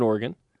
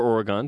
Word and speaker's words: Oregon: BYU's 9-10 away from Provo, but BYU's played six more games than Oregon, or Oregon, depending where you're from. that is incorrectly pronounced.